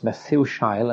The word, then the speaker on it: Methushael: Adam, Cain, Enoch, Irad, Mehujael, Methushael, Methusael in the Authorized Version, but pronounced Methushael, and this is how it is in the NIV, Lamech.